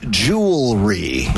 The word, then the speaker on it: jewelry: And Colin, it is jewelry. – Not jury? – Yeah, Domino says jewelry.